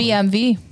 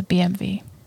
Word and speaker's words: BMW: It's BMV. – What? – That's the sound. That's how you pronounce a W. – Oh, gotcha. Yeah, gotcha. – It's a BMV.